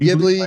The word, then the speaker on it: Ghibli: if that's even how you say it. – Ghibli, I'm trying to say it exotic, so it's Ghibli,